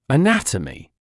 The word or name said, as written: anatomy